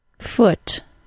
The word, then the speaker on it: foot: I'm going to say the words pronounced with the OO sound first. School. Two. Room. Food. Choose. And here are the top five other U words. Good. Book. Look. Foot. Cook.